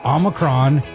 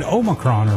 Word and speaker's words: Omicron: Officially, almost anything works. Both Omicron and Omicron are fine. The Oxford Dictionary includes Omicron, and in classical Greek, it was pronounced with the second syllable sounding like me.